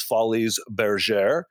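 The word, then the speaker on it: Folies Bergère: But Jennifer has continued to headline in over 20 shows around the world, including Legends in Concert, the world famous Follies Bergère.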